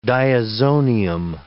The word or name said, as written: diazonium